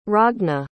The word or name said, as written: ragna